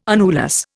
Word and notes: annulus: Indian

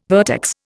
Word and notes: vertex: Indian

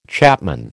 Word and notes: chapman: American English

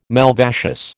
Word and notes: malvaceous: American English

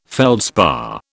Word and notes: feldspar: British English

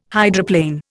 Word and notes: hydroplane: Indian

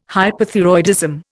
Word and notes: hyperthyroidism: Indian

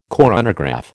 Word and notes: coronagraph: American English

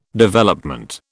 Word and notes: Development: British English

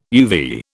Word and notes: uvea: British English

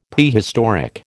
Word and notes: Prehistoric: American English

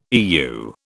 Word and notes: Eu: British English

